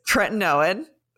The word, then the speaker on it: tretinoin: Tretinoin.